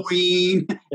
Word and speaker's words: Joaquin: That was a shockingly American pronunciation. – Yeah, I went as sharp as I could. – El Chapo. – Joaquin.